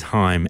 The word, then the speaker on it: Haim: Now, I'm pretty sure it's pronounced Haim. I have been calling them Haim for the longest time, but someone corrected me not that long ago.